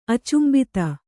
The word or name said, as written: ಅಚುಂಬಿತೆ